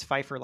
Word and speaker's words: Pfeiffer: My line is when everyone asks how to pronounce my name, I say it's Pfeiffer like Michelle, which was especially joyful to be able to tell Michelle.